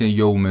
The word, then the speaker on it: یوم: خب عنایت دارید که این کلمه یوم یش فتحه داره، صدای ا داره، لذا اینطور تلفظ بکنیم، بگیم یوم الدین و نه یوم الدین، یو، یوم الدین.